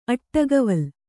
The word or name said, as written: ಅಟ್ಟಗವಲ್